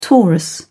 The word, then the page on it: taurus: British English